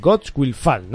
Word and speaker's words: Gods Will Fall: Y vamos a ver si de verdad pues ha, valido la pena y ha salido un gran juego como es, eh, a ver si lo digo bien, Gods Will Fall, ¿no? Sí, señor, abajo con los dioses.